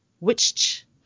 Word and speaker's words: Wittich: witch. – What? – And I say it like that because it's W-I-T-T-I-C-H, like witch. – W-I-T-T-C-H. – W-I-T-T-I-C-H.